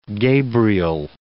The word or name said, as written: Gabriel